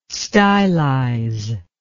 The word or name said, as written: stylize